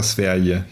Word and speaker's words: Sverige: Eskra Sverige. Ich glaube, man muss die Gs muss man so richtig wegschludern. – Ja, so wie der Franzose das H, ja? – Ja, genau.